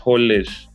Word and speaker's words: Holeš: Tomáš Holeš, Holeš, is it pronounced Holeš or Holeš? – Holeš. – Yeah, Holeš.